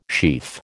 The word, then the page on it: sheaf: American English